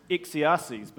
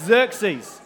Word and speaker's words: Xerxes: Xerxes, say after me, Xerxes. All right, good. You're feeling smarter already, right? Like, whoa, Xerxes. I thought it was Xerxes, but it's not. It's Xerxes. Okay.